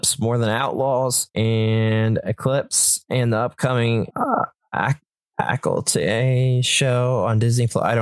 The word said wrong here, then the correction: Acolyte: I thought it was like Acolyte. – Acolyte.